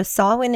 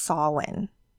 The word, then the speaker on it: Samhain: So Samhain is how you pronounce it. It is spelt, it looks like Samhain, the way it's spelled, S-I-A-M-I-A-N, I think is how it's spelled. But it is pronounced Samhain. That's the actual Gaelic Celtic pronunciation.